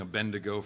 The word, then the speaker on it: Abednego: Remember, the D goes before the N. I was saying Abednego for a long time before I got it straight.